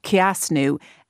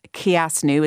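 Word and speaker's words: Cneasú: She runs Kiasnu Endometriosis Awareness, and you will find her on Instagram, Kiasnu. It's spelled C-N-E-A-S-U, Father, and that's the word for healing in Irish.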